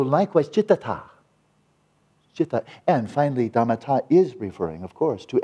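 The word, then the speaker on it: dhammatā: The phenomenon-ness, dhammata. Because that ta, T-A with an akron, a long A, dhammata, is just, what is it about these phenomena that makes them phenomena, right?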